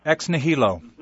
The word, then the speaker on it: ex nihilo: And I don't believe it's, I'm pronouncing it right, ex nihilo? – Ex nihilo. – Ex nihilo. That's correct.